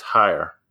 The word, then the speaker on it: Hyre: It's Hire. H-Y-R-E.